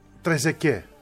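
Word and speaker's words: Trezeguet: Όπως για παράδειγμα, ας πούμε, Τρεζεκέ. Εντάξει, ο Τρεζεκέ, όταν έπαιζε, έχει τάφει στο τέλος. Που δεν προφέρεται.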